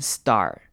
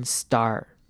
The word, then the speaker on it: star: 这边这个字要这句要特别注意的是 star 这个字，st 开头的字我们会念成 st 的声音，就是念成 d 的声音，st st action star action star。